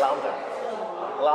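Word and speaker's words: round: レフト、レフト、ラウンド、ラウンド。これは N の発音注意。 – そうそう、え、 N、あの最後の単語の最後の N を言い忘れるんですよ。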